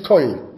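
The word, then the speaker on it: Kol (כל): Nein, das ist Kol, das ist mit Kaf. Das ist Kol, das ist Kol, Kol mit Kaf.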